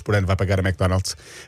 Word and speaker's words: McDonald's: Ligue 1 McDonald's.